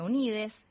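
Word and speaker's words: Unidxs: Se escribe con X, Unidos, se pronuncia Unides.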